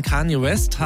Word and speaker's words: Kanye West: Nach der Trennung von Kanye West habe ich es richtig ausgesprochen? – Ja.